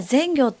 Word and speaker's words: 善行: 山下さん、もともとこの全業というこの地名なんですが、はい、住んでいる人は全業って読めるんですが、実はちょっと良い行いと書いていい名前ではあるんですが、ちょっと漢字としては難しかったりしますよね。